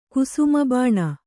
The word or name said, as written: ಕುಸುಮಬಾಣ